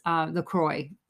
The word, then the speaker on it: LaCroix: I'm saying that wrong too. That is LaCroix.